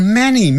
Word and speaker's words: many: 那有许多青蛙呢？There are many frogs，就要改用 there are，或者用 many， many 就是多的意思，许多的，m a n y， many， m a n y，不要念成 m a n y 啊。